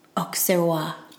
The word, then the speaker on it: Auxerrois: And this guy said that the national grape of Luxembourg is called Auxerrois.